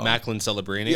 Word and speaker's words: Celebrini: Macklin Celebrini. – Yeah, Celebrini.